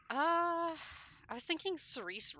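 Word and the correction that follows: Saoirse: Ronan. – Sorsha, I think it's pronounced. – Is that how you pronounce that? I'm so sorry. – I think it's actually pronounced Sorsha, but... – All right. – I may be mistaken, but I think that is the Celtic pronunciation.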